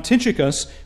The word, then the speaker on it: Tychicus: The character then from this list to whom I would direct your attention this evening is this man, Tychicus, and I confess I mispronounced his name before. The CH is hard in the language before us. It is this man, Tychicus, which comes before our attention now.